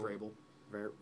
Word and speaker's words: Vrabel: My coach of the year is Mike Verbal. Or Verbal. – Vrabel.